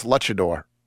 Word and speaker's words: luchador: Never owned them never had them so there you go is it how do you spell how do you pronounce luchador Luchador.